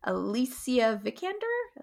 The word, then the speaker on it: Alicia Vikander: I mean, I think, and I'm probably going to butcher the actress's name, Alicia Vikander? Alicia Vikander? – I would have said Alicia, but yeah, Vikander, sure.